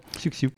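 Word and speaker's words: Xiu Xiu: Ouais, Xiu-Xiu. – Xiu-Xiu. – X-Xiu. – Ouais, ouais. Ça se dit Xiu-Xiu et ça s'écrit Xiu-Xiu.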